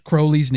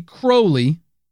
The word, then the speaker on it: Crowley: Ozzy pronounced Crowley's name wrong. It's actually Crowley, not Crowley.